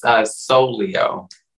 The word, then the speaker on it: Souleo: You can say it's SOULEO.